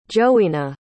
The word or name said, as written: johyna